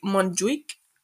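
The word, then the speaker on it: Montjuïc: at the Montjuic, which I believe is the right pronunciation, in the Estadio Lluís Companys.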